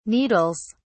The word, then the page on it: needles: British English